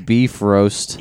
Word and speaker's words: Bifrost: It's pronounced beef roast.